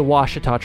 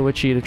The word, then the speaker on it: Ouachita: And at the time, we were pronouncing the name of the trail wrong. We were calling it the Ouachita Trail, I think, something like that. And that is the wrong pronunciation, so don't hate me for that. We later discovered it was incorrect. And so, yeah, it's the Ouachita Trail, not the Ouachita Trail.